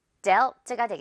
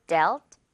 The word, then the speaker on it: dealt: 역시 발음 여러분 이제 어렵지 않죠? dealt가 되겠습니다. deal 그리고 dealt.